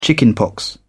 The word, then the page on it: chickenpox: British English